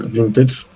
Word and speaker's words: Vintage: bo to nie jest wcale gitara, gitara za jakieś grube tysiące, tylko kopia Gibsona Les Paula, firmy vintage, tak? Tak to się wymawia? Tak się wymawia.